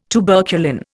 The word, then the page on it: tuberculin: Indian